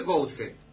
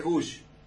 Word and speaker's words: Gouge: Entonces, el San Rafaelino viejo, o sea, se escribe Gonge, ¿no es cierto? – Gouge. – El de la edad mía para adelante es Gush. Pero el antiguo, el abuelo, los bisabuelos, era Gonge.